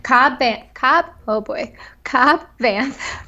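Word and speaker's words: Cobb Vanth: Like, it took until I was, like, kind of just perusing the internet today, and it was like, Cad Banth from the, or how do you say his name? Cobb Banth from the Aftermath series makes an appearance in the Mandalorian.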